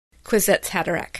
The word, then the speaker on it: Kwisatz Haderach: Talking about whether or not Paul was Kwisatz Haderach.